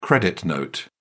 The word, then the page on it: credit note: British English